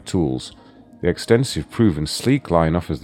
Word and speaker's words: Slique: Right, now one thing that's jumped out at me here is what's known as the Sleek Range. It's spelled Slique. – That's my shake. Sleek.